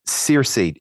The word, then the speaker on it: Circe: So Circe is not spelled like it sounds unless you're familiar with um, Homer's Odyssey. It's spelled C I R C E.